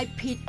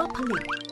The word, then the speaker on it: ผลิต: คำว่าผลิตออกเสียงเป็นสองพยางค์ว่าผลิต